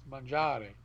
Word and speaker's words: mangiare: You gotta roll your R. It's mangiare.